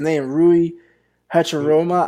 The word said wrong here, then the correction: Hachimura: I don't know, like, bro, is he gonna do something? I don't mean to butcher his name. I'm so sorry to the Asian community. I'm not trying to butcher. – Rui Hachimura. – Hachimura. Sorry for the Asian community.